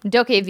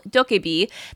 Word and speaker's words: DokeV: DokeV. – DokeV. – Okay, it's like Tokapri. Tokapri from Pokemon.